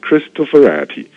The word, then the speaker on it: Cristoforetti: 我们意大利文要念成 Cristoforetti，意大利人哈。